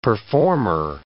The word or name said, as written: performer